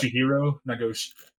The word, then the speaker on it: Toshihiro Nagoshi: Toshiro Nagoshi talks Ghost of Tsushima. How bad did I butcher that? – Toshihiro Nagoshi. – Nagoshi. Okay.